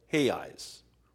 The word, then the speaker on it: reais: Now, fortunately, the dollar is okay in Brazil, so I give them a dollar and they give me two, what's called reais, which is spelled R-E-A-L. They don't pronounce it. They pronounce the R as a H sound.